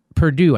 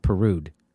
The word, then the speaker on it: Perdue: Lee, really, Pete Lee, Flake, and Perdue, I think it's supposed to be, not Perud.